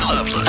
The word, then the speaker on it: Loveless: more in the Hank FM studio with Patty Loveless. – I married a guy from North Carolina. His name is spelled Lovelace, but they pronounced it Loveless.